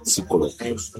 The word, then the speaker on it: psicológicos: Estaba recordando unos hechos psicológicos, porque la P no se pronuncia, psicológicos.